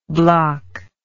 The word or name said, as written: Blok